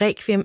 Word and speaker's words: requiem: Han er også en meget sprogkyndig mand, og han forklarer også, at den danske udtale af requiem er noget, man har til fælles med andre germanske sprog. Hvorimod man i Italien for eksempel vil udtale ordet med rulle-r og så det her ku. Altså skal jeg prøve at give det et skud? Requiem.